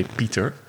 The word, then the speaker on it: Pieter: Well, my name is Pieter, which is spelled P-I-E-T-I-E-R. So it's like Pieter, okay. – But in Dutch you say Pieter.